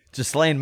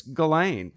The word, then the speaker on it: Ghislaine: I guess it's Ghislaine. Ghislaine. Yeah, I guess the S is silent in that.